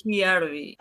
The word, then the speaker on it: Hiarvi: Ahora sí, entrando en calor, Yarby, bienvenida y me corrige por favor, si pronuncio bien tu nombre. Sí, es Giarbi.